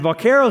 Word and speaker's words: vaquero: And vaquero is a fun word.